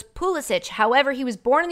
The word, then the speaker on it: Pulisic: He's from the United States, prefers his surname to be said as Police-Sick.